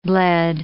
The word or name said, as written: bled